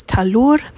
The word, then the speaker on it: Tahloor: My name is Talour. That's spelled T-A-H-L-O-O-R.